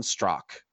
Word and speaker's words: Straka: And people can find me at Brandon Strock. And my last name is spelled S-T-R-A-K-A. Yes, it's very confusing with that silent A at the end.